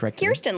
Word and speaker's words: Kirsten: I watched an interview with her on Jay Leno once and she said, it's Kirsten, you. Like ear.